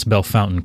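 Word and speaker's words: Bellefontaine: Of Bell Fountain made a contribution. When I could pronounce Bell Fountain correctly is when I felt like I was in a real Ohioan.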